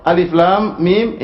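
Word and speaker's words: Alif, Lam, Mim (الم): Alif, Lam, Mim. Jangan dibaca alam. Keliru. Jangan dibaca alam, ya.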